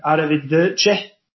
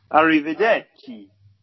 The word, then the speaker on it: Arrivederci: 7-1 Benson, 9-1 Arrivederci. Got there in the end. – What a great pronunciation. – Go on, you give it a go then. – Arrivederci.